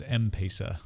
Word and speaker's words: M-Pesa: And it's M-Pesa is how you say that.